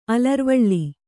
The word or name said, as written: ಅಲರ್ವಳೆ